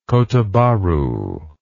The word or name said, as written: kota bharu